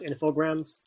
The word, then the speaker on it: Infogrames: First of all, it's pronounced Infogrames, but nobody cares because whatever.